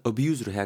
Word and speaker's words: abuse: 어, 동사니께는 당연히 발음은 abuse로 해야겠죠. abuse.